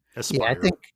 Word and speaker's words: Aspyr: I wonder if like Limited Run Games will end up publishing it, because I think it's Asper that's doing the trilogy. – Yeah. Aspyr. – Aspyr. I've been saying it wrong.